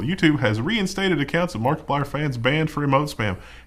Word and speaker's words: Markiplier: How do you pronounce this guy's username? – Markiplier. – Oh, I read it as Markiplier. – That's terrible. – Like, I put an emphasis on the I. – I hate notes. That. – Definitely Markiplier.